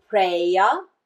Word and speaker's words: Preya: There is the choice that's called Preya. The English pronunciation would be P R E Y A, Preya. And there is the one that's called Shreya, S H R E Y A, Preya and Shreya.